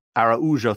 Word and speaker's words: Araujo: Araujo, thank you, sir.